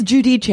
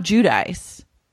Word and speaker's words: Giudice: It is Giudice. It's not Giudice. – Yeah. – Okay. I'm calling it Giudice from now on because that's the Italian pronunciation and I refuse anything other than that.